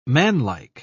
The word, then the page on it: manlike: British English